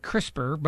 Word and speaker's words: CRISPR: The technique used to edit the genetic material is known as, I've been calling it CRISPR, but here's how it's spelled, C-R-I-S-P-R. C-R-I-S-P-R is potentially capable of not only removing disease from the human genome, but also enhancing traits such as intelligence and beauty.